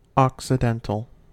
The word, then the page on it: occidental: American English